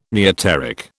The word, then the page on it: neoteric: British English